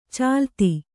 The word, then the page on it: ಚಾಲತಿ: cālati